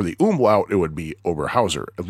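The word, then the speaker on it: Oberhäuser: But yeah, at first glance, it looks like it's Oberhauser, but the A has an umlaut over it. And A with an umlaut followed by a U, according to this book, if I remember correctly, is actually pronounced Oi.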